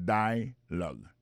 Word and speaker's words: dialogue: Alors, mon dialogue, ça, c'est dialogue. Ça ne peut manquer là, nous peut manquer que l'accent tonique là tombe sous premier syllabe là.